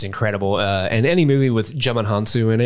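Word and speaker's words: Djimon Hounsou: I always say Jemin Hansu. – Jemin Hansu.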